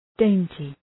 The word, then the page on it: dainty: ‘deıntı